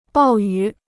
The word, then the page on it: 暴雨: bào yǔ